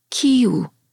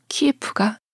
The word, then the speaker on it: 키이우: 키이우. 러시아어 발음으로 키에프가 바로 이 도시입니다.